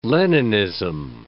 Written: Leninism

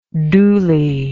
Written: doolie

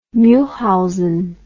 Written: Mulhausen

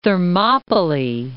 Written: Thermopylae

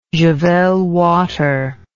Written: javelwater

